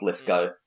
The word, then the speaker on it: Lithgow: Yeah, but also, like, the interview stuff on some of the movies, he calls himself Lithgow, so he probably knows how his name is pronounced.